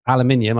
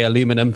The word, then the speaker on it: aluminium: Teller also advocated for putting a cloud of aluminium, I should say aluminum for your American listeners. – No, please say aluminium. It's actually the proper way to say it.